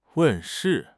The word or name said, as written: 问世